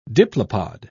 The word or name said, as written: diplopod